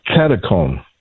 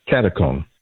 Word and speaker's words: Katechon: How do you pronounce this, and what is Katechon? – Katakon. Katakon. – Katakon.